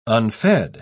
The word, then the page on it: unfed: British English